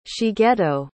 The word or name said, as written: shigeto